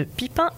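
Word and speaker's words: Pippin: donc j'espère qu'on dit bien Pipin. – Oui. Pipin, ouais, voilà, c'est ça, il n'y a pas de prononciation à l'anglaise.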